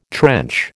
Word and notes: trench: American English

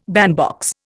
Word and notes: bandbox: Indian

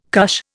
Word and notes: cush: Indian

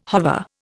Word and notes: hover: Indian

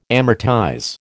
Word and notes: amortize: American English

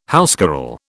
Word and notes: housecarl: British English

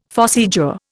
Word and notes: phossy jaw: Indian

